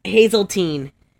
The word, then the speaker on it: Hazeltine: Hazeltine. It's spelled teen. Like Listerine.